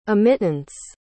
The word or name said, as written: emittance